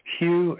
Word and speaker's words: Hugh: It's Hugh, H-U-G-H, the last name Charlson, that's spelled T like Tom, R-A-U-L, S like Sam, E like Edward, M like Nancy.